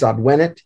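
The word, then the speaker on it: advēnit: Yes, you lengthen the E. So the present is advenit, short E. The perfect is advenit, A-D-V, long E-N-I-T. Advenit. All right. Adveniunt. – Adveniunt. – Adveniunt. – Yeah. – Adveniunt. A-D-V, or A-D-V long E, N-E-R-U-N-T.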